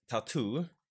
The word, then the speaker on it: t.A.T.u.: Eh, och namnet Tattoo alltså man uttalar det som man uttalar det engelska ordet tattoo som tatuering typ, men det är också en förkortad version av en rysk fras som jag inte ska uttala för jag kan inte ryska.